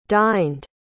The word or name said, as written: dined